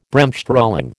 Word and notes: Bremsstrahlung: American English

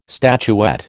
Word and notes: statuette: American English

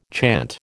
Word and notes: chant: American English

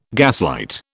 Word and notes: gaslight: British English

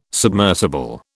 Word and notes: submersible: British English

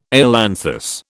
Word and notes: ailanthus: British English

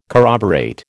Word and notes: corroborate: American English

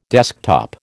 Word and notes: desktop: American English

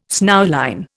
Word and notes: snowline: Indian

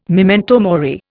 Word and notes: memento mori: Indian

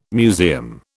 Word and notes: museum: British English

